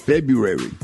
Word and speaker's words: February: A lot of people have a hard time with February. – Yep. – Yes, yeah. Pronouncing the R. – N-B-R-U.